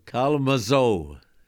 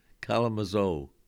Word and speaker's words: Kalamazoo: They'd say Kalamazoo, Kalamazoo. Yeah, that's the way they pronounce it, like they would wanted to say it right. That's the way of pronouncing the A and the L and Mazo. They didn't say zoo, they said zo.